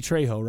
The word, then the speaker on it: Trejo: He called him Danny Trujillo, but it's Danny Trejo, right? – Trejo, yeah.